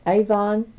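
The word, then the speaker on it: Avonne: My name is Connie Avon Petty. It's C-O-N-N-I-E A-V-O-N-N-E P-E-T-T-Y, and I live near Delight, Arkansas, in Pike County.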